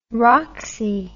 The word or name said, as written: roxy